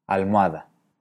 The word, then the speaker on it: almohada: Veamos ahora la palabra almohada. Aquí, los tres pronunciamos las vocales O y A como diptongo wa. Almohada.